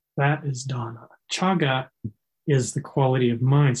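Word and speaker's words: cāga: But there's a Pali word, chaga, and if you're interested in Pali, it's spelled C-A-G-A, chaga.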